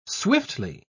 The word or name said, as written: swiftly